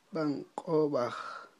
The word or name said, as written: b'a'n q'ob'aj